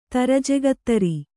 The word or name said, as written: ತರಜೆಗತ್ತರಿ